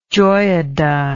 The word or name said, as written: Joiada